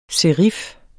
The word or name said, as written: serif